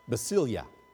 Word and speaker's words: basileia: Then we have the word kingdom in our text, and the Greek word is pronounced basileia.